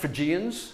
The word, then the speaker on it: Phrygians: Phrygians.